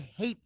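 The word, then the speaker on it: Haight: You pronounced it funny. – Yeah, I know you were going to. I know. It's my fight. You know what? – And it's the Hate Street District.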